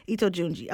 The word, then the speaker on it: Ito Junji: But today we're going to talk a bit about Ito Junji. Okay. So what is so special? And did I say his name right? – Perfectly.